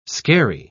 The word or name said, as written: skerry